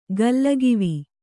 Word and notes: ಗಲ್ಲಗಿವಿ: gallagivi

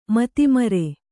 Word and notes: ಮತಿಮರೆ: mati mare

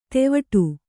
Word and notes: ತೆವಟು: kannada

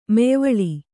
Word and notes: ಮೆಯ್ವಳಿ: meyvaḷi

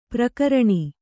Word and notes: ಪರಕರಣ: Kannada

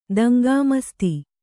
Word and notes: ದಂಗಾಮಸ್ತಿ: daŋgāmasti

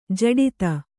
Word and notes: ಜಡಿತ: jaḍita; Kannada

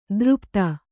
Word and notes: ದೃಪ್ತ: Kannada

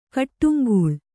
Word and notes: ಕಡಂಗೊಳ್: kaḍaŋgoḷ

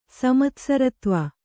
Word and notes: ಸಮತ್ಸರತ್ವ: Kannada

